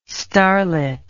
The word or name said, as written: starlet